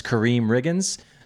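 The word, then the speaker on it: Karriem Riggins: Is it Kareem Riggins? – Yeah. – All right. So an artist is Kareem Riggins.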